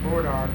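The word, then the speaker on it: bois d'arc: It's spelled B-O-I-S, apostrophe D-A-R-C. French name. They call it burdock, or wood of the ark.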